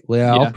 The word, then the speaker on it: Leal: Leal, pronounce it like he's a Brazilian.